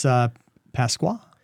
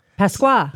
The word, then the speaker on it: Pasqua: It's Pasqua. – Pasqua.